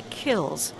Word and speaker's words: kilns: He calls the ovens which fire the china kills, spelled K-I-L-N-S, but potters don't pronounce that N.